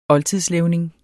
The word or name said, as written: OLDTIDSLEVNINGER